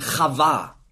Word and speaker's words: Chava: Eva, hebräisch ausgesprochen Chava.